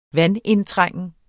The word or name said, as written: vandindtrængen